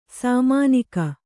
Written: ಸಾಮಾನಿಕ

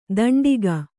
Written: ಧಾಂಡಿಗ

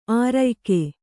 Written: ಆರ್ಯಕೆ

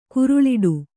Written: ಗರಳೊಡೆ

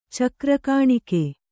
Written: ಚಕ್ರಕಾಣಿಕೆ

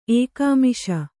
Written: ಏಕಾಮಿಷ

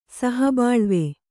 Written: ಸಹಬಾಳ್ವೆ

